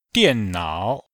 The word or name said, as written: dian4nao3